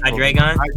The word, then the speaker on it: Hydreigon: Hydri, I can never pronounce this. – Hydreigon? – Hydri, yeah, the three-headed dragon.